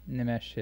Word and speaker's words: Nimesh: Nimesh, yeah.